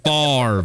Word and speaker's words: Favre: You tried to bribe Tony, Mr. Favor. Favre. It's pronounced Favre.